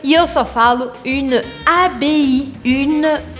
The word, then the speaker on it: abbaye: Porque vocês lembram que A com I eu falo E e I sozinho, eu falo I. E o E no final, eu não pronuncio. É por isso que então eu vou falar A, B, une abbaye.